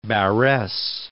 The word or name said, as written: Barres